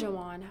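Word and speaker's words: Juwan: Is that Juan? How do you say that? Jawan? – It's Jawan Howard.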